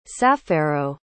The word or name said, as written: sapphero